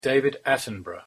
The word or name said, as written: David Attenborough